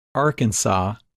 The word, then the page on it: Arkansas: English